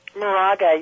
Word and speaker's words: Moraga: Did I say that right? Moraga? – Moraga, yes.